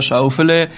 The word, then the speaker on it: Schauffele: Denn seine Familie stammt aus Schwaben und sein Name würde dort wahrscheinlich deutlich anders ausgesprochen werden, weiß unsere Schwabenexpertin. – Naja, dann wäre der Alexander Schauffele. Das wurde auch sicher über viele Dekaden so ausgesprochen, weil tatsächlich seine Familie aus Stuttgart kommt.